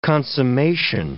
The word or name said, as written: consummation